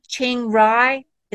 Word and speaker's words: Chiang Rai: It's an all-inclusive vegetarian wellness retreat in, now I'm going to hopefully not say this wrong, Chiang Rai.